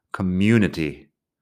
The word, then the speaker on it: community: Fíjate que pronunciamos you ahí en medio, community.